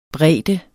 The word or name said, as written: bredde